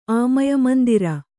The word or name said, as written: ಆಮಯಮಂದಿರ